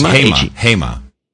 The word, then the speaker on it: Hema: There's a store, it was a group of stores since you're in Hall, and I'll mention this, called Hema. How do you pronounce it? – Yes, Hema. – Hema? – Hema. H-E-M-A.